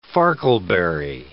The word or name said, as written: farkleberry